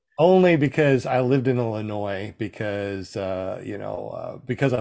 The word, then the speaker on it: Illinois: Why does Illinois have a S at the end if you're not supposed to pronounce it? – You know? I know. And when you hear people say Illinois, it just makes your skin crawl.